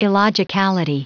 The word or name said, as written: illogicality